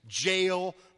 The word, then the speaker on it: Jael: They rout the enemy, and the leader, this guy Sisera, he gets away to the tent of Heber's wife, whose name is Jael, rhymes with nail, reminds you of a tent peg through the head.